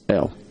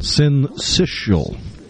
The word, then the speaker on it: syncytial: Syncytial. – I honestly have no idea. – Me either. I think Here's a pronunciation. Syncytial.